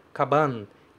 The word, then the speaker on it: kaban: A outra é na palavra kaban, que é bem parecido com o que eu falei em gohan, certo? Kaban, kaban. Fala o A, kaba, depois você fecha com n.